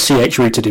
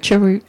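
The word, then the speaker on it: chroot: in a sort of kind of like a ch-rooted environment, but implemented differently. – Do you pronounce it ch-root? I always said ch-root. – Yeah, I say ch-root.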